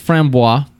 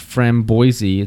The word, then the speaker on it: framboise: So on the bottle it says Lindeman's Framboise, and that's how it's not pronounced framboise, it's framboise, is a lambic made from local barley, unmalted wheat, raspberry juice, aged hops, and wild airborne yeast.